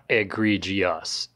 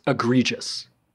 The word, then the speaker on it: egregious: That's not how it's pronounced. It's not egregious. It's egregious.